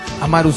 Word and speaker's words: Amaru: Amaru sin acento en la U, Tupac Amaru, dice Esteban. Me pasa el dato, te lo digo yo que soy peruano.